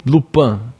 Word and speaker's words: Lupin: lupan Lupin, Lupin, Lupin hum.